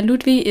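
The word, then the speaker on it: Ludvig: Und äh, Ludwig ist in Deutschland ein sehr seltener Vorname, ähm, was wahrscheinlich daran liegt, dass wir alle nur den den Namen Ludwig kennen mit W und ausgesprochenem G. Deshalb belegt die skandinavische Version von Ludwig, also Ludwi, äh, nur Platz 284 der, beleg der beliebtesten männlichen Babynamen.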